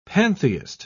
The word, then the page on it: pantheist: British English